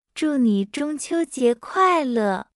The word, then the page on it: 祝你中秋快乐: 0.7x